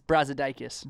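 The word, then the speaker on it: Brazdeikis: They're led by a freshman, I'm going to butcher this name, Ignis Brodskius? – It's Brazidakis.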